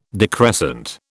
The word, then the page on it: decrescent: British English